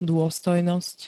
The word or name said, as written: dôstojnosť